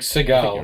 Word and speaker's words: Sagal: with Katie Sagal, is that how you're pronouncing her name? – Oh, Sagal. I thought it was Katie Sagal, yeah. – Yeah, I'm pretty sure. It's like Sagal. – You're right.